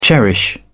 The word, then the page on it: cherish: British English